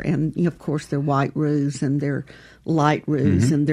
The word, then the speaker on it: roux: And I remember the first time a Mississippi girl heard the word roux, I went, ooh, what is that? And it's spelled R-O-U-X, which is a French word.